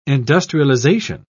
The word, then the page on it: industrialisation: British English